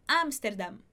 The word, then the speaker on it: Amsterdam: Em português a gente fala Amsterdam e em espanhol Amsterdam. E a gente fecha a boca no final. Amsterdam. Amsterdam. Perfeito? A sílaba mais forte é a primeira.